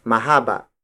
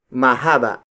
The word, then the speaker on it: mahaba: Haba is spelled as H-A-B-A. It is defined as the measurement between two points. As an adjective, haba becomes mahaba. Mahaba. Mahaba. Mahaba is spelled as M-A-H-A-B-A.